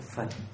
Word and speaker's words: Phật: Còn chúng ta theo tàu cái chữ đó đọc là Phật, là âm Hán Việt,